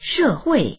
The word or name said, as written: she4hui4